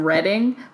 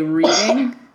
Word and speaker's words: Reading: Or like, my parents live in Reading, but people always say Reading. They go Reading. Yeah. And I was like, it's Reading, like Reading Railroad, you know? – Yeah.